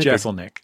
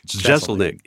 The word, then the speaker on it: Jeselnik: Well, Anthony, does he pronounce his last name Jeselnik? – Jeselnik. – Jeselnik. Yeah.